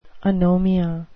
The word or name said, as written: anomia